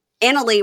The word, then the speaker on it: Anali: Annalie. We're supposed to say Annalie. We were saying it wrong.